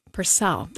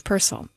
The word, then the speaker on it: Purcell: Jumping immediately into music of Purcell, or I should say Purcell. You know, that was always the hardest name for me to pronounce when I first started doing classical music radio hosting. I always thought it was Purcell. It's not, it's Purcell. Henry Purcell.